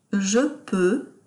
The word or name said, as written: je peux